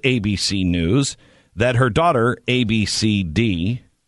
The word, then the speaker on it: Abcde: It's not right to make fun of her name, A-B-C-D-E. – Is that a pronounceable name, or is it legitimate? – Of course it is. It's Ab-C-D. Ab-C-D. – Ab-C-D. – Ab-C-D. – Mm-hmm. – Ab-C-D. But it is spelled A-B-C-D-E.